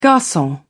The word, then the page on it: garcon: British English